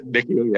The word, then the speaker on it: NICU: When I got to that NICU, how do you pronounce it? NICU? – NICU, yeah.